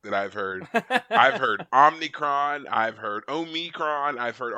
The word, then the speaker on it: Omicron: As all Star Trek fans know, you pronounce it Omicron. We've been pronouncing it this way for decades. I'm sorry. Omicron. You're not gonna win this one. You need to go back and watch some more Trek, David. You will see it's Omicron.